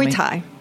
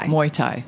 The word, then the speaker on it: Muay Thai: Tell Muay Thai. – Me. – Muay Thai. Very close to Mai Thai.